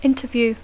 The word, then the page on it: interview: English